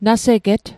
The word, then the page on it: naseget: Mi'gmaq